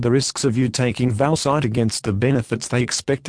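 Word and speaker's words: Valcyte: This is the consumer medicine information for Valsite, powder for oral solution, pronounced Valsite, contains the active ingredient valganciclovir.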